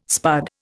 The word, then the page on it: spud: Indian